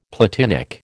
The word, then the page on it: platinic: American English